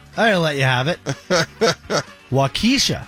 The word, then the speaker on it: Waukesha: Yeah, it's Waukesha.